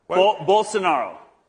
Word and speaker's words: Bolsonaro: Barcelona, if I say it right, Bolsonaro. – Bolsonaro. – Okay, Bolsonaro. He's going to be coming here to speak in a couple hours.